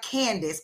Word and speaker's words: Candace: My name is spelled C-A-N-D-A-C-E. It's spelled like Candace, but pronounced Candace Holmes, H-O-L-M-E-S.